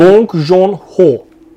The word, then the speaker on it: Bong Joon-ho: Gong Joon. Bong Joon Ho.